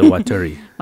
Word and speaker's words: Waturi: And these native islanders were called the Waturi. Did I pronounce that right? – Yes, the Waturi.